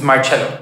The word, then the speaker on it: Marcello: How do you pronounce it? It's Marcello.